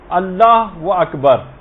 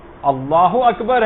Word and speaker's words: اللہ اکبر: بہت سارے غلط تلفظ لوگوں کے اندر رائج ہیں، مثلا اللہ و اکبر، اللہ اکبر ہے یہ، اللہ و اکبر نہیں.